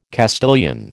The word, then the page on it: Castilian: American English